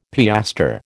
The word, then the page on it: piastre: American English